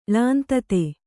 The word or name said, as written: ಳಾಂತತೆ